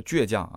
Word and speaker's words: 倔强: 然后呢，也有很多的朋友给我的这个发音啊提了一些要求，说呃三刀是最后的倔强，不是最后的倔强啊，这个读第二声倔倔倔倔，读倔强。